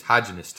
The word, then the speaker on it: hygienist: When they say, hygienist. – Have you been flossing? – I don't think you're saying it correctly. It's hygienist.